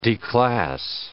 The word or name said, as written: declass